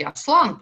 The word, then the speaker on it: Аслан: Я только напомню напомню очень важную вещь, что вообще-то э, логичнее а, говорить, э, э, ставить ударение, как привычно русскому слуху, он все-таки Аслан, потому что это тюркское слово, и Льюис прекрасно это знал, когда он говорил, использовал это слово, потому что по-тюркски Аслан означает лев, но это я, мягко выражаясь, не первый, кто об этом говорит.